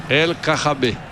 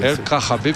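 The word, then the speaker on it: El Kaabi: El Khabi. – El Khabi. – El Khabi με το χο.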